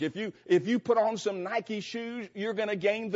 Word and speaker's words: Nike: It is a form of the Greek word nike, which we, of course, spell N-I-K-E and pronounce Nike.